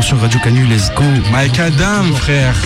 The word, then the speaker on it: Micadam: Mike Adam, c'est comme ça que vous prononcez. Mike Adam, si si.